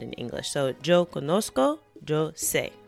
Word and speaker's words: conozco: And that's spelled C-O-N O-Z-C-O, o en español, C-O-N-O-Z-C-O. And it's important to recognize that the Z in Spanish is not pronounced like the Z in English. It's pronounced a lot more like the letter S. Entonces, it's not yo conozco. Es yo conozco.